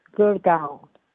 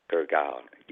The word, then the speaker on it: Gurgaon: Gurgaon, how do you pronounce the city in India that you live? – It's Gurgaon. – Gurgaon, yes.